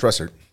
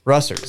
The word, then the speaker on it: Russert: Yeah, it's Russert. – Russert.